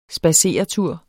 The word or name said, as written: SPADSERETURE